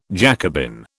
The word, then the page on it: Jacobin: British English